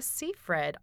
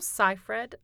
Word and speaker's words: Seyfried: starting with Amanda Seyfried, also Seyfried, I've heard her name pronounced, so I think you could say either.